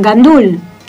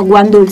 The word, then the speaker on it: gandul: Y también, por ejemplo, se come el arroz con gandul, que en algunos países se pronuncia como guandul.